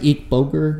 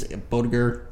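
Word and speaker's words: Boettger: And how do you pronounce it? Eek Boger? Is that how you pronounce it? – Bodiger. – Yeah, Bodiger.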